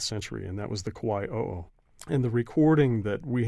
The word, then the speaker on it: Kauai o'o: Tell us about the Kauai o'o. Am I saying that right? – You are.